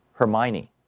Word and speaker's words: Hermione: And if anybody knows her or knows how to pronounce this particular first name, H-E-R-M-I-O-N-E. Hermione? Hermione. Hermione, Sean says.